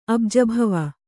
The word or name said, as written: ಅಬ್ಜಭವ